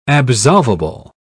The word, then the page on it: absolvable: British English